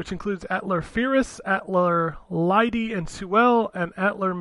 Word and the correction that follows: Atelier: If you follow the Atlar games, which I'm sure I'm pronouncing that wrong, it's Atelier or something like that. – Atelier, yeah, I think.